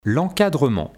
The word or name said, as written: l'encadrement